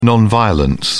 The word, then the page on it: non violence: British English